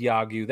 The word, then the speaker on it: Yagyu: This hat says Yagyu. That's how you pronounce it. It means baseball in Korean, and that's part of our KBO collection.